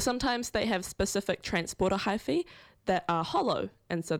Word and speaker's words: hyphae: So with regard to how they actually move from tree to tree via the mycorrhizal network, the mycelium have, I believe it's pronounced hyphae? Yes. I literally did an entire degree in this and pronunciation still trips me up really bad. So it's hyphae, that the nutrients move along through.